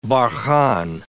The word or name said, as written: barchan